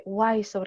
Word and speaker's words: Wiseau: y ya lo busqué, Wiseau, porque no sabía cómo se pronuncia, Tommy Wiseau, y cómo él ideó esta película.